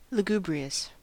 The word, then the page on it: lugubrious: American English